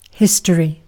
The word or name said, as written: history